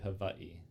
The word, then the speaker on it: Hawaii: There's the W of V. – Hawaii. – Okay. – Well, yeah. It's technically Hawaii. – Oh, okay. – The W is a V. But Hawaii is also acceptable, just not Hawaii.